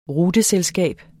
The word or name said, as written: ruteselskab